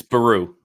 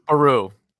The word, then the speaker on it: Beru: It's Beru. – Beru.